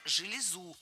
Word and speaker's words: железу: Правильно – железу. Ударение на окончание. И оно сохраняется во всех формах единственного числа.